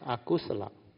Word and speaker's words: akusala: That's why they say under Abhidhamma, when you input the content of consciousness, this content of consciousness, there are a few types, kusala and akusala. You pronounce it like Malay word, very easy.